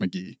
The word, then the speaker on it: Mick G: And Mick G, the singer, we're told, is pronounced McGee. Even though this man has a project earlier in his career called Pronounced M-C-G-E-E. – To be fair, that's McGee. – But could also be McG. Phonetically, G-E-E is G. – I'm going to keep it a buck with you. That's McGee.